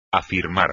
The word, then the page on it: afirmar: espagnol